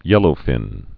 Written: yellowfin tuna

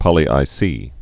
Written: poly I:C

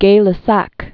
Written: Gay-Lussac

